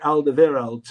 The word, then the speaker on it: Alderweireld: A lot of people call Toby Alderweireld Toby Alderweireld. But if you watch Toby Alderweireld say his name, he says Toby Alderweireld. So I don't know why you'd call him Toby Alderweireld, because he doesn't say it that way.